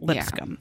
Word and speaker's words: Lipscomb: So we have 30-year-old Kathleen, now it's spelled Lipscomb. – Yeah. – But our narrator says Lipscomb, and I think that's how you say it, Lipscomb.